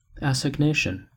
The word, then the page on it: assignation: American English